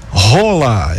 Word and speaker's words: Haaland: Os atacantes de 25 anos, Vini Júnior e o Erling Rola. É, você pronuncia Rola, não tem nada de Haaland, Rona, é Rola, o correto é Rola.